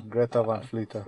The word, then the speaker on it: Greta Van Fleet: Let's talk about Great Event Fleet. – Great Event Fleet. – Apparently we're saying the name wrong. – What is there to talk about? – Apparently it is fucking pronounced Greta.